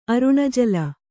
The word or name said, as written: ಅರುಣಜಲ